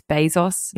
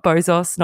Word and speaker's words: Bezos: According to the man himself, it's Bezos, not Bozos, not Bezos, not Bezos.